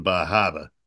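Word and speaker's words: Bar Harbor: We went through the northern part of New Hampshire, and to get us closer to Bahaba, you know, because that's how you say it, Bahaba.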